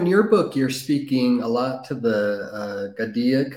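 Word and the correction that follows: Gaudiya: I don't know if I pronounced that right. Is that the right pronunciation, Swami? – Which words you use, sorry? – Gadia? – Gaudia. Yeah, Gaudia.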